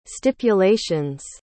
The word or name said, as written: stipulations